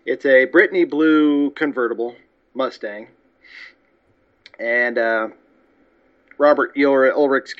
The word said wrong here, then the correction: Urich: character, did I say that right? – I think it's Urich. – Urich. Oh, boy. Well, I did voice text or voice typing, and I really screwed that up. Sorry, bud. Robert Urich.